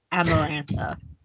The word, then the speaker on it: Amarantha: See it because I kept wanting to say Samantha, but it's Amarantha. – I keep wanting to say Samantha. – Amarantha. – Yeah, Amarantha.